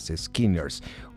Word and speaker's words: SKNNRS: Échenles una oreja, se escriben S-K-N-N-R-S, Skinners, una banda liderada por Juan y las chicas.